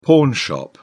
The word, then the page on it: pawnshop: British English